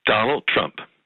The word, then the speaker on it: Donald Trump: Donald Trump.